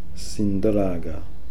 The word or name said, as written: Sinndraga